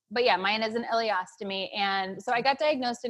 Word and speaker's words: ileostomy: So I guess what were your thoughts, when you found, first, how did you get to the point of needing the, am I saying it right? Ileostomy? – Yes.